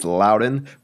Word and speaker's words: Loudoun: Well, according to the talk radio programs that I listen to throughout the day, it's Loudoun, but there's a random N in there that makes you wonder, like, is it something else? It must be like a silent N.